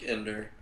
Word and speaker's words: Ender: Maybe more like Ender. I mispronounced it. – Ender? – Yeah. – So Ender from Ender's Game.